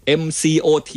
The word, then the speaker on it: MCOT: MCOT